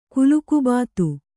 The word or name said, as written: ಕುಲುಕುಬಾತು